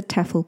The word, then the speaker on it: TEFL: So what's a TEFL course? T-E-F-L, pronounced TEFL for short, stands for Teaching English as a Foreign Language.